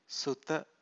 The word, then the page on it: suta: Pali